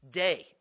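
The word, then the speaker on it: day (δεῖ): The Greek word is DEI, day. It's pronounced day, DEI.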